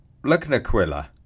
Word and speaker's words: Lugnaquilla: Uh, uh Quilla, which you struggled to pronounce, is pronounced Lugnaquilla.